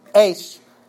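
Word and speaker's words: eis (εἰς): It is a preposition, E-I-S in the Greek, "eis" is how it would be pronounced, and the preposition has up to 8 different nuances of meaning.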